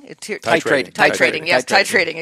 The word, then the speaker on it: titrating: It disrupts sleep sometimes, and it's called tirating. – Titrating. – Excuse me, I didn't want to say that wrong.